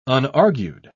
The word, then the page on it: unargued: British English